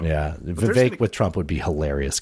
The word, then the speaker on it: Vivek: Rhonda, and Vivek, rhymes with cake, just hate fucking each other.